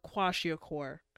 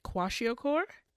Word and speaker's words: Kwashiorkor: very bad because of that conflict, you know, people know about kwashiorkor, which yeah.